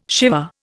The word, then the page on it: Siva: Indian